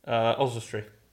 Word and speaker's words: Oswestry: Oswestry.